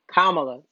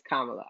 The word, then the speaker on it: Kamala: Kamala. See, I said it wrong again. Shout out to Shakima for teaching me. Her name is Kamala.